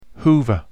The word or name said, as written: hoover